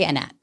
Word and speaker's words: Anat: Is that pronunciation correct? – I'm not even totally sure. Sometimes I ask my family and they're like, Anat, Anat. – What do you say? – I say Anat.